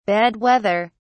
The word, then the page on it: bad weather: British English